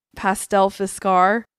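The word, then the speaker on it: pastellfiskar: In Sweden, the candies were originally called, okay, here we go, pastelfiskar. – Spell it. – It's spelled like pastel. Oh, wait, okay. P-A-S-T-E-L-L-F-I-S-K-R. Wait, K-A-R, car, K-A-R, yeah.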